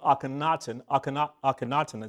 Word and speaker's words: Akhenaten: The Pharaoh of Egypt, Akhenaten is the way I pronounce his name, he also practiced this, simply being at one with spirit.